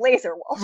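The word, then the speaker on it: Lazar Wolf: I mean, it's spelled L-A-Z-A-R. – Mm-hmm. – But, it sounds like Laser Wolf. – But still but Laser Wolf. – No one says Lazar.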